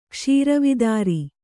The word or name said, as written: ಕ್ಷೀರವಿದಾರಿ